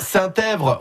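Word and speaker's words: Saint-Epvre: Alors, Saint-Èvre, on prononce pas le P, hein, on est bien d'accord.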